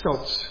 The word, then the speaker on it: Celt: The Celts, by the way, don't pronounce it Celts when you're out there in Scotland or Ireland now. Correct you, Celt. The C is pronounced K, Celt.